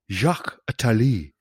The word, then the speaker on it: Jacques Attali: Jack Satale was, or Jack Satale, I better get my pronunciation right, or somebody will give me a rollicking again. Jacques Attali, is that better?